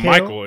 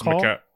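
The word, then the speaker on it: Mikal: It's not Michael. It's McCall. Oh, it's, it's Mc- definitely not Michael.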